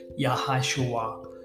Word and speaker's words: Yahashua: It looks like Yahshua, or an extended version of Yahshua when you read it, but it's pronounced Yahashua.